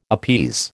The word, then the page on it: appease: American English